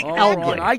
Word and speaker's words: Elgin: They pronounce it incorrectly in Illinois. – Oh, so Elgin, Illinois is incorrect, Elgin Baylor is incorrect, and the Elgin Watch Company pronounces it. – All wrong. It's Elgin.